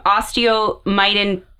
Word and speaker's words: osteomyelitis: But she suffered from osteomyelitis.